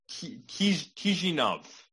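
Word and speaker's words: Chișinău: It's Chisinau is how you pronounce it.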